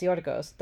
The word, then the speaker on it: Yorgos: Yorgos, right? Or is it... Yeah, it's Yorgos.